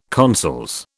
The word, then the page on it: consols: British English